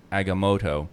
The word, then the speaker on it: Agamotto: And in that record, it's pronounced Eye of Agamotto.